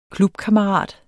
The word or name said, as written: KLUBKAMMERAT